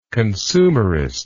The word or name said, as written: consumerist